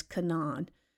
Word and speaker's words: Canaan: The land of Canaan, and by the way, some people pronounce it as Canaan, was inhabited by a variety of people, the Hivites, the Girgasites, the Jebusites, Amorites, Hittites, and several others.